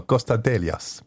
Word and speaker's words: Konstantelias: Sorry, hast du falsch ausgesprochen. Nochmal, Konstantelias.